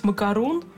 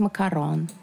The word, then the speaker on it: макарон: А, я их пыталась выговорить макарун, макарунс. – А да, их, ну, оказывается... Э, правильно говорить макарон.